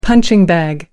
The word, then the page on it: punching bag: British English